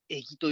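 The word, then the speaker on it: 餌木: エギっていうのはですね、まあ、あのカタカナで我々今、エギって書くんですが、漢字で書くとですね、餌の木と書いてエギと読むんですね。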